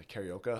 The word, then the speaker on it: karaoke: But yeah, is that how it's pronounced? – Yeah, karaoke.